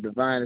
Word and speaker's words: Devon: Devon Harrison. – Can you spell that for me? – First name Devon is spelled D-E-V-O-N. Last name Harrison is spelled H-A-R-R-I-S-O-N.